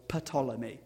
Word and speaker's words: Ptolemy: The king of the south, Ptolemy in Egypt, or Ptolemy, as it's more accurately pronounced.